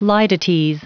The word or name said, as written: litotes